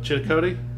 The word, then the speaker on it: Chillicothe: Chillicothe? – Chillicothe.